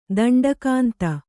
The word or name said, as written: ದಂಡಕಾಂತ